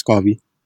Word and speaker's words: Garvey: Or yeah, it's Garvey.